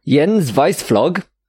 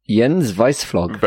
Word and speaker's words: Jens Weissflog: Jens Weissflog.